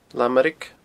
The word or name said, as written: Lamairig